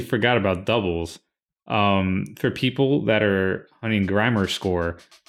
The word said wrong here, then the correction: Grimoire: I don't know why I pronounced it like that. Grimoire. I'm just going to say Grimoire.